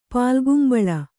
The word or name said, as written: ಪಾಲ್ಗುಂಬಳ